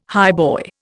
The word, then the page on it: highboy: Indian